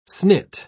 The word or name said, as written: snit